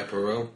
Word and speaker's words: Poirot: Her fictional detectives were, I'm going to say it wrong, Hercule Poirot. – Yeah, Poirot.